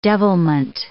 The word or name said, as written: devilment